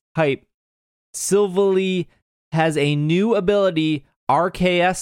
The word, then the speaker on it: Silvally: There's Silvally. No E to make it like Valley. – Silvally? Yeah, so it's more like Silvally.